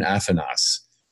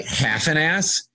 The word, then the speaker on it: Afanas: So, of course, I jumped at it to play the Baron Afanas. It's spelled A-F-A, like Afanas, but I think, is that like half an ass or Afanas?